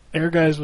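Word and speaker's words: Ehrgeiz: Air Guys. – Air Guys. – Okay. It is basically Air Guys with a story. – No. – That's pretty much what it is. – Air Guys was playable.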